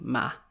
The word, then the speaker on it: mig: Jeg kan bare godt lide at hygge mig. Ah, at hygge mig. You know, mig often gets pronounced like ma. Jeg often gets pronounced like ja.